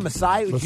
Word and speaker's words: Masai Ujiri: The Raptors general manager, how do you say his name? Masai Ujiri. – Yes.